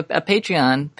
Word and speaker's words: Patreon: We have a Patreon patron. That's how you say it.